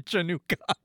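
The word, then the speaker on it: Chanukah: So Hanukkah spelled with a C-H? – Yeah, that's one of the, you can spell it different ways and like the C is silent. It's just like happy Chanukka.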